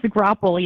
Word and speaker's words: Graupel: Is it Graupel? How do you pronounce that? We were talking about that before. – It's Graupel. Yes. You had it right.